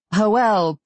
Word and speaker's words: Joel: Joel, pronounced Joel in the Spanish style, was Hispanic, but his accent was southwestern USA.